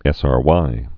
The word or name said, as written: SRY gene